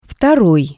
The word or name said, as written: Vtoroi